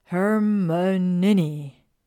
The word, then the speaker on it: Hermione: How to correctly pronounce her name. Hermione, she said slowly and clearly. Her Monini. Close enough.